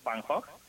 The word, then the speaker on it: Van Gogh: Vincent Van Gogh, Vincent Van Gogh. – Yo quiero oír, quiero oír, ¿eh? Sí, Van Gogh es como se pronuncia realmente el apellido, pero bueno.